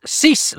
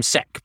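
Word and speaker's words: Cec: It's Cees Linder, short for Cecil. Cees Linder. Okay. Although in the UK they called him Sec, but he pronounced it Cees.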